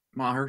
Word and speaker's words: Maher: Is Maher how you say it? – Maher, sure, yeah.